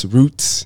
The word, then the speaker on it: Ruuts: June 3rd, Roots, pronounced R-U-U-T-S, Roots.